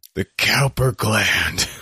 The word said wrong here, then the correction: Cowper: It's pronounced Cooper. You Philistine.